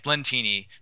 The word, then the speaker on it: Lentini: Fehlandt, yeah, it's Lentini. They call him Fay apparently, so you can just yell Fay if you're at a game. Um, but Lentini kind of rhymes with Martini.